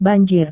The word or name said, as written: banjir